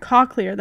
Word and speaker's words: cochlear: It's called a colloquial. I can't say it. – Cochlear? – Cochlear. That's how you say it.